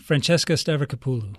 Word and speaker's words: Francesca Stavrakopoulou: Francesca Stavrakopoulou.